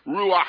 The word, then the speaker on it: ruach: there's a word for spirit and wind that's ruach. But you have to say it like this. Ruach. Yes. Yeah. Get it in the back of your throat.